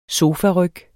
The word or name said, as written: SOFARYG